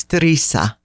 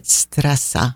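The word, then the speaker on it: Stresa: And I have been mispronouncing that village name forever. It's Strisa. – Strisa. – I was pronouncing it Stressa.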